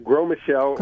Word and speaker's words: Gros Michel: Am I saying that right? – Yeah, the Gros Michel.